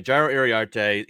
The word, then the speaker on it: Jairo Iriarte: Yeah, and you did say it right. At least to my knowledge, you said it right. So yeah, Jairo Iriarte, he's really been a pop-up guy this year.